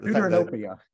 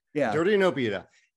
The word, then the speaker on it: Deuteranopia: Deuteranopia. – Deuteranopia.